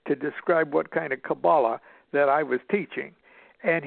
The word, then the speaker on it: Qabalah: He says, "You don't even pronounce the word right. It is Qabalah, Qabalah."